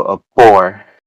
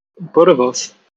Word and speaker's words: boerewors: So this one, I know I'm going to butcher this one, but so a boar. – Boerewors.